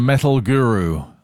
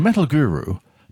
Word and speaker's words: Metal Guru: A tune from that amazing hit-making machine, T-Rex, with Tony Visconti controls and Metal Guru on Planet Rock. You have to say it like that. You can't say Metal Guru.